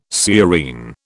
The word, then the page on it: sciurine: British English